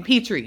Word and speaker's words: Petrey: Petrey.